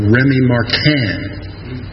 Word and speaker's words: Rémy Martin: And then imported beers, top it off with some cool Courvoisier or Rémy Martin. You don't quite pronounce the N in there, you know.